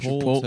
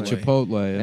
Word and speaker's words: Chipotle: They swapped the letters. – Chipotle. – But it's so fucking easy to say Chipotle.